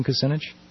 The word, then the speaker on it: Kucinich: Diana's wondering, in that order, is that, you know, Kerry, Dean, Kucinich, is that the order in which, and am I mispronouncing Kucinich? – That's how I understand to pronounce it.